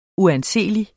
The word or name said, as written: uanseelig